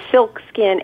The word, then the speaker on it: SilcSkin: Most of the photos that we have on our site, which is SilcSkin, S-I-L-C, S-K-I-N.